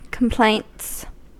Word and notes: complaints: American English

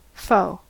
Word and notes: foe: American English

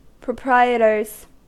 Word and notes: proprietors: American English

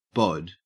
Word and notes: bod: Australian English

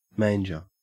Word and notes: manger: Australian English

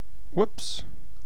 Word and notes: whoops: British English

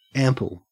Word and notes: ample: Australian English

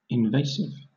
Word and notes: invasive: British English

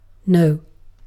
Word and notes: noh: British English

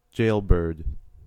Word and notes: jailbird: American English

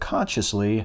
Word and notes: consciously: American English

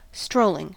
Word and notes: strolling: American English

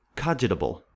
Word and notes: cogitable: American English

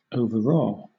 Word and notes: overawe: British English

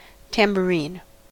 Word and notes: tambourine: American English

